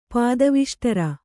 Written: ಪಾದವಿಷ್ಟರ

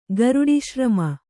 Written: ಗರುಡಿಶ್ರಮ

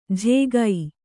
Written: ಝೇಗೈ

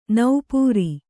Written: ನಪ್ಪುರಿ

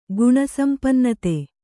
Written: ಗುಣಸಂಪನ್ನತೆ

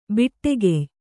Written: ಭಟ್ಟಗೆಯಿ

